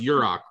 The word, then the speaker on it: Yurok: Yeah, the it's pronounced Yurok, right? Is that the correct pronunciation? – Yep.